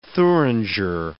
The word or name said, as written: Thuringer